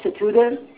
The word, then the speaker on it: Scoodeh: My name is Mickey Satuda. – I'm sorry, can you spell that for me? – Mickey, N-I-C-K-Y, Satuda, S-C-O-O-D-E-H.